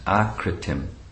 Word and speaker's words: akritim: Next, the word that's selected to expand the meaning of is akritim. Not short A, but long A. A, as in akritim.